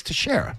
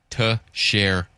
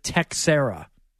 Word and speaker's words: Teixeira: I would go to a Sterling call, that's how you say it. It's Teixeira. – Baseball Reference Pronunciation Guide. Teixeira. – Teixeira. – Teixeira. – Which they ask the players, how would they like their name pronounced? – Correct. – That's how that works. – Right. Soucy does. Soucy goes through this with the Patriots. Like they, you know, they give you a snippet. If it's questionable, they put you on video. – Cappy's Continental Portuguese pronounce it Texera.